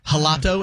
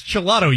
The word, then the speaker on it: gelato: And I thought it was like a soft G, like you might pronounce a J in Spanish. So I was like, I'll have the gelato. And he's like, the gelato. Yes, yes. – Yes, yes. – Yes. – It's just gelato, you idiot.